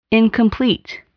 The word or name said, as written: incomplete